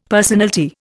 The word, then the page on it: personalty: Indian